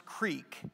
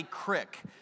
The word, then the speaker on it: creek: A couple things to say before we get started with the message this morning, and that is that the word is pronounced creek, Jason. It is not a crick.